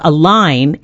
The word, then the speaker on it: Align: It's called Align, and it rhymes with sign.